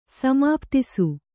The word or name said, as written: ಸಮಾಪ್ತಿಸು